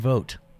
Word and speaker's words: Vogt: You can find me at Brendan Vogt.